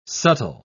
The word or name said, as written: subtle